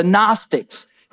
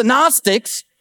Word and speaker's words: Gnostics: John the beloved, the writer of this gospel account, when John is writing this, there's a group of people around called the Gnostics. It's a uh, spelled G-N-O-S-T-I-C-S. The G is silent like the K in fish.